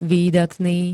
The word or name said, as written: výdatný